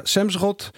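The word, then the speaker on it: Semsrott: Dus ja, toen kreeg je de groene europarlementariër Semsrot. Zeg ik dat goed? Ja, Semsrot.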